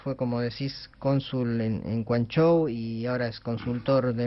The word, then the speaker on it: Guangzhou: Tenemos hoy acá con nosotros a un experto, Mario Quinteros, que fue cónsul argentina en Guangzhou, no sé si lo dije bien. – Acá decimos vive... Cantón, ¿no? – Pero Guangzhou. Guangzhou.